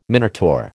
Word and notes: Minotaur: American English

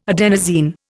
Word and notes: adenosine: Indian

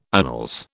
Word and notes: annals: British English

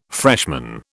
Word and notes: freshman: British English

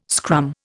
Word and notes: scrum: Indian

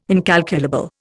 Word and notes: incalculable: Indian

